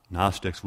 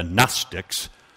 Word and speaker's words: Gnostics: You had the Gnostics with a silent G, J-N-O-S-T, the Gnostics, like our word to know with a silent K.